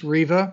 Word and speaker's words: Riva: No, it's, it's Italian. It's Riva, which okay.